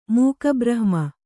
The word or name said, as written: ಮೂಕಬ್ರಹ್ಮ